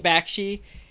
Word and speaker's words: Bakshi: Cause it really how do you say his last name? So this is by Ralph, is it Bakshi? – Bakshi.